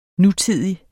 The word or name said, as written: nutidig